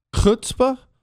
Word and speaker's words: chutzpah: Chutzpah? Is that what? Chutzpah? Did I say that right? I did? Is that not? That's closer. Oh, that was right.